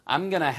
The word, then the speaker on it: gonna: I'm gonna have lunch with Tom today. 그래서 바로 I'm going to의 발음이 going to가 going가 됐다가 바로 gonna가 됐다고 그랬죠?